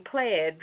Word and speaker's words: Plaid: Well, Department of Justice is trying to block Visa's planned acquisition of a financial tech startup company called, I believe it's pronounced Plaid, P-L-A-I-D. So I'm going to call it Plaid.